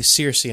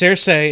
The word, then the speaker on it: Cersei: I actually heard that's the actual pronunciation. Cersei? It's Cersei is the actual pronunciation. Like George R. R. Martin said that is the pronunciation, but everybody says Cersei.